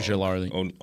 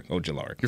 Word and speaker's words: Ojulari: Ojulari. Yep. – Okay, Ojulari.